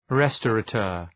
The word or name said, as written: restaurateur